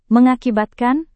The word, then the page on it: mengakibatkan: Indonesian